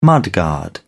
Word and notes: mudguard: British English

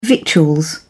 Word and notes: victuals: British English

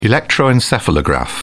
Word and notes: electroencephalograph: British English

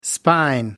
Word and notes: spine: American English

